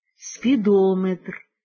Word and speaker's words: спідометр: Стосовно ж назв вимірювальних приладів із складовим «метр», то у них наголос ставиться на передостанньому складі «барометр», «спідометр», «термометр».